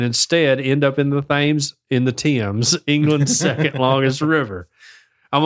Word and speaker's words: Thames: I think they call it the Thames. I think that's how they pronounce it. – The Thames.